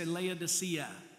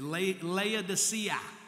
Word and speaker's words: Laodicea: Everybody say Laodicea. Oh, say it like you're not asleep. Say Laodicea. There you go. Laodicea.